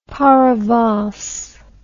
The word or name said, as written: paravance